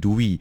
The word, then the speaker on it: Dwi: W I Dui 哈，那其实真正的名字是 D W I，也是一样念成 Dui，但是呢，这个呃学生呢，子涵呢，他就是念成 Dui，所以大家会一直想说 Dui Dui 很怪。